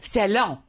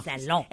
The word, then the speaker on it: salon: Salon. – Oh. Salon. – It's a salon. – No. – That's not how you say it en français. En français, salon.